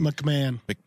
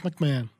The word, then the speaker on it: McMahon: I don't know how to pronounce his name. – McMahon. – Is that really it? – McMahon? Yeah, Vince McMahon.